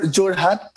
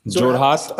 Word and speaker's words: Jorhat: Am I am I pronouncing it Jor, right? Jorhat, Assam. – Jorhat, Jorhat, Assam.